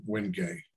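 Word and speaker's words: Wenge: Most of the bows I've made in the past couple of years have had the Wingay. It's called spelled W-G-N-G-E, but pronounced Wingay.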